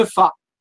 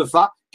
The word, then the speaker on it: Fa: Et un de ces points, c'est la tour de Fa. Alors la tour de Fa, c'est un petit village, ça s'écrit F-A, comme la note de musique.